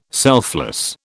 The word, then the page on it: selfless: British English